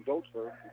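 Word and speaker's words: Dotzler: Grabbed by Dossler. Kim Dossler into the front court for the Badgers. To Michael Wickham. Back to Dossler. Excuse me, Tim Dotsler. You pronounce a long O.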